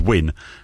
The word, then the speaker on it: Wynne: It's called Win, which is spelled W-Y-N-N-E.